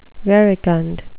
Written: verecund

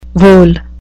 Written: vole

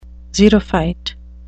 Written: xerophyte

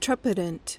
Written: trepidant

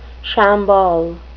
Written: shamble

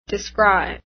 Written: descry

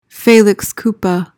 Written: felix culpa

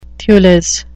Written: thewless

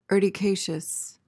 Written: urticaceous

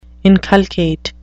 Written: inculcate

